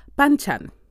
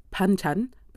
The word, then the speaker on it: banchan: Now, I said banchan, but I am willing to bet that in the Oxford English Dictionary, it's pronounced banchan.